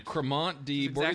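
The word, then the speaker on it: Crémant de Bourgogne: Cremant de Bourgogne. – Exactly how you say it.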